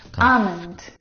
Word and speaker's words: almond: Almond. – Huh? – Now, in this word, the L is silent.